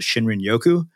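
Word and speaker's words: Shinrin-yoku: and correct me if I've pronounced this wrong, I believe they call it Shinrin Yoku. – Correct.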